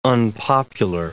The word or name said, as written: unpopular